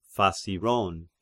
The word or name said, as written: Phasiron